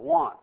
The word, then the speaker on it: wont: There's this word in the New Testament that we don't use anymore in our English language, W-O-N-T. Pronounce it want. It's not like want, W-A-N-T, but it's W-O-N-T.